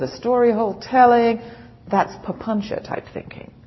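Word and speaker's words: papañca: That's papuncha. That's a fabulous word. I love this word, papuncha. There's a whole talk on papuncha. You should have regular talks on papuncha. P-A-P-A-N-C-A. The C is pronounced ch. Papuncha.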